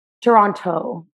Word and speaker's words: Toronto: Toronto. My grandma gets really mad at me when I don't pronounce the T's, but no one from Toronto actually says it though.